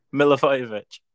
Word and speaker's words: Milivojevic: Milivojevic.